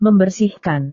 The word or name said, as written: membersihkan